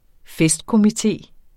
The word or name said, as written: FESTKOMITEEN